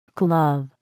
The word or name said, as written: glove